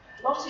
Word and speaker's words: Lot: Lot is pronounced in the Hebrew as Lot, So don't be surprised if you hear lots of people like that's the way to pronounce it.